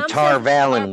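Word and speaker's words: Tar Valon: In the glossary of the Great Hunt right now, it's Tarvalon. No, it's Tarvalin. Sorry. – Tarvalin. – It's Tarvalin. Sorry. – Yeah. – They're them Tarvalin witches.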